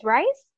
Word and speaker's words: Rice: Is Rice? – Yeah. – Like it's actually pronounced Rice. – Yeah, it's actually pronounced Rice. – All these years I thought it was Reese.